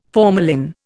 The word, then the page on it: formalin: Indian